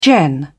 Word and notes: gen: British English

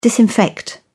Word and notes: disinfect: British English

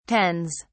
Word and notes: tens: British English

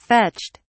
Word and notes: fetched: British English

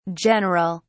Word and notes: general: British English

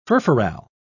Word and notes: furfural: British English

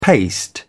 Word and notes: paste: British English

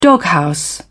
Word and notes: doghouse: British English

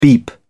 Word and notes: beep: British English

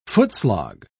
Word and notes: footslog: British English

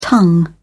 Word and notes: tongue: British English

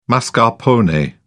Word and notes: mascarpone: British English

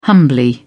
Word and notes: humbly: British English

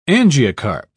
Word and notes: angiocarp: British English

